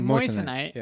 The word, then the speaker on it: moissanite: It's pronounced moissanite.